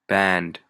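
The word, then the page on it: band: Canadian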